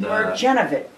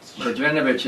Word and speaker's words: Mrdjenovich: Mirjanovic. – Mirjanovic is M-R-D-J-E-N-O-V-I-C-H. The D is silent.